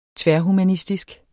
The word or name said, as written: tværhumanistisk